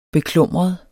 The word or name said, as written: beklumret